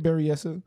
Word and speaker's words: Berryessa: It is B-E-R-R-Y-E-S-S-A. Berryessa or Berryessa? – Or what was the letter after the Y? – E. – Huh. – Yeah, see what I mean. – Okay. I'll give you Berryessa. – All right, Berryessa it is.